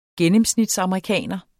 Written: gennemsnitsamerikaner

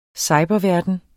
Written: cyberverden

